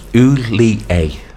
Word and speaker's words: Hollier: Yes, pronounced Hulier. – Hulier. All right, then, Yeah. – Just like Gerard.